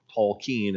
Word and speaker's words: Tolkien: J.R.R. Tolkien, or Tolkien as he pronounced it himself, and Charles Williams and C.S.